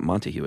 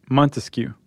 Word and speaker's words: Montesquieu: How do you pronounce that? It's not Montague, is it? – Montesquieu. – Montesquieu? – Uh-huh.